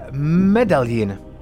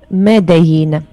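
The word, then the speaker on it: Medellín: Medaļina. – Es neredzu. Tur ir L vai nē, tur ir rakstīts nu, ar L medaļu. Divi, divi L izrunājas kā J spāņu valodā.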